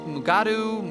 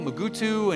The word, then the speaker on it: Mugato: Mugato, you know, and just changing that like crazy. – So I was reading up on the Mugatos. Did you know they have alternative pronunciations? Mugatu, Mugutu, and Gumato?